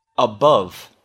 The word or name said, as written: above